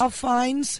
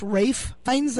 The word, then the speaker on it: Ralph Fiennes: The last time I was there, there was a big poster because the person I thought whose name was Ralph Fiennes is actually pronounced Rafe Fiennes.